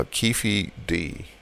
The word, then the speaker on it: Keefe D: But Keefy D, uh, Keefy D, that's how you pronounce it, Keefe D, um, said that he was supposed to get money from Puff Daddy.